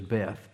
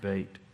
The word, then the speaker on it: Beit: Looks like it says Beth, but it's pronounced Beit.